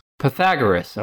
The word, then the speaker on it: Pythagoras: Pythagoras, okay.